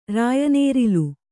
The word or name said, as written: ರಾಯನೇರಿಲು